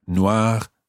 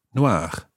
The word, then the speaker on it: noir: With noir, the E being added onto the end doesn't make any difference to the pronunciation of the masculine form, noir, noir. They sound exactly the same.